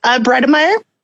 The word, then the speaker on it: Bredemeier: Bredemeyer. – Spell that for me. – B-R-E. D-E-M-E-I-E-R.